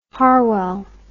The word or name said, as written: harwell